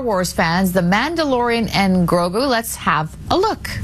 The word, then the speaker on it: Grogu: Yeah, that's Grogu.